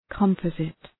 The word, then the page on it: composite: {kəm’pɒzıt}